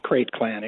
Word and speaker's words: Krayt: Which means Sergeant in Arms from the Krayt clan. Did I say it? – Krayt clan, exactly.